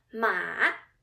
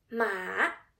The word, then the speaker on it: 马: The first Chinese word with the third tone is ma. It means horse in English. 马.